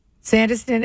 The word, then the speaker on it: Sandyston: Sandyston and Hardyston. But they pronounce the Y just like it's a short I. How about Sakasana, who people who live there call it Sakasana, like a sauna, S-A-U-N-A, and it's Metuchen, clearly Sakasana.